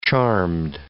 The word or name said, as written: charmed